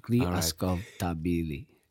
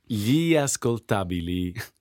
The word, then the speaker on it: Ascoltabili: Ascoltabili. The way you say it is the way you spell it.